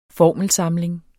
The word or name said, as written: formelsamling